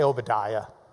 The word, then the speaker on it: Ovadiah: And this is a Hebrew B, but if it doesn't have a dot in the middle, it's pronounced as a V. Victor, V. So this is Ovadiah. We say Obadiah.